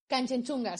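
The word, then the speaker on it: Kangchenjunga: Porque nos falta hablar de cachenchuya ¿Lo dije bien? Canchenchunga, sí.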